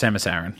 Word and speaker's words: Samus Aran: it's pronounced Samus Aran.